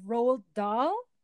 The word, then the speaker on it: Roald Dahl: It's a book by Roald Dahl. – Oh, okay. – Okay. – Yeah, that's how you pronounce the name.